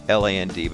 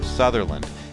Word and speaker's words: Sutherland: Now, if you look for her stuff online, it's Sutherland, but she pronounces it Sutherland.